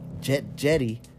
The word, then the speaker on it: Djedi: and it's like Jetty was the first magician, like a master magician, and it's spelled D-J-E-D-I, and that's from like...